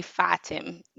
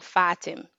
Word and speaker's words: Fatim: So for the longest time, it's like, yes, I'm Fatim, but it's actually Fatim. – Fatim. – Yes, yes. The emphasis is on the A, not the I. So Fatim.